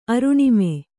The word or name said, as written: ಅರುಣಿಮೆ